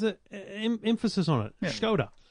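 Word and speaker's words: Škoda: Skoda. – It's pronounced Skoda. – With a H.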